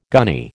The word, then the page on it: gunny: American English